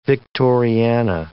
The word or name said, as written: Victoriana